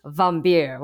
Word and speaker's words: Wambier: It's spelled W-A-M-B-I-E-R, but it's pronounced Carlos Vambier, which is a great name.